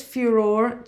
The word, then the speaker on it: furore: So this furore, do you pronounce it furore or furore? – Furore. – Let's just do it in the Italian way, because it's a fucking weird word.